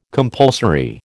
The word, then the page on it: compulsory: American English